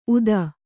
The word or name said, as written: ಊದ